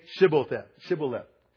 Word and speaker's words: Shibboleth: Say Shibboleth. And so if they couldn't say it because they couldn't pronounce their SHs, they couldn't say sh, they would say Shibboleth instead of Shibboleth.